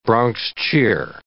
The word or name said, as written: Bronx cheer